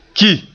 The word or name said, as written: ki